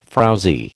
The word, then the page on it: frowzy: American English